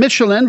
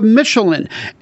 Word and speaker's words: Mechelen: Let's talk about the home side first, though, of Michelin. M-E-C-H-E-L-E-N, but I think I'm getting at least a little bit closer to the Dutch, pronouncing it like the tire.